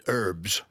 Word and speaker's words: herbs: They call it herbs. – Yes. – They don't pronounce the H.